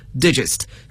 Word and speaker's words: digest: 大家都知道它做名词是还可以表示文摘，但是这个文摘呢就不能读作 digest，而要读作 digest，所以拼写相同，可是发音词性各不相同。